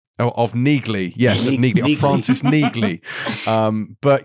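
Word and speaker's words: Neagley: I'm not going to call it Neely because it's pronounced Neegley. There's a G. It's not silent.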